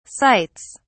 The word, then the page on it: sights: British English